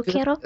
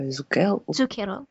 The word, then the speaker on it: Zucchero: Alors, le mot italien, parce que Zucchero. Zucchero. – Zucchero, vous le prononcez mieux que moi, il est les Vénitiens, Venise était un port important.